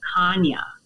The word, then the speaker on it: Chania: C-R-E-T-E, which is a Greek island in the Mediterranean Sea, specifically the town of Chania, which is spelled C-H-A-N-I-A, Chania. – Kanye.